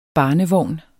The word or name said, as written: barnevogn